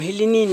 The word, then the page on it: hilinin: Panim